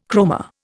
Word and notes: chroma: Indian

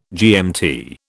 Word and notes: GMT: British English

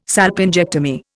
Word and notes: salpingectomy: Indian

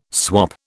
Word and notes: swop: British English

